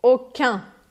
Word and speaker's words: aucun: No feminino, eu vou pronunciar esse U mais U francês mesmo. E eu vou fazer o biquinho. Aucune, aucune. Por que isso? Porque a letra E no final vai me forçar a pronunciar o N.